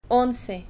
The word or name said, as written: onze